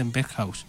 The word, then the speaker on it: Berghuis: Ουσιαστικά προφέρεται, νομίζω είναι Μπέρκχαους. Από ό,τι ξέρω, κανονικά είναι B-E-R-G-H-U-I-S τελειώνει το όνομα του, αλλά νομίζω προφέρεται Μπέρκχαους.